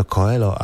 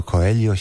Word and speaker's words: Coelho: Ja zawsze myślałem, że to Coelho, a to Coelho się mówi.